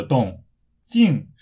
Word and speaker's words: 动静: 动是运动的动，活动的动；静是安静的静。不过声调不是四声，而是轻声，也就是没有声调。所以在中国大陆。正确的发音应该是动静，动静，没有动静，有动静，发音非常重要。